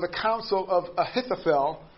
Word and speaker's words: Ahithophel: But he prays a prayer about Ahithophel. Said that wrong. Ahithophel. There we go. There we go. That sounds better.